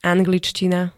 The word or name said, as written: angličtina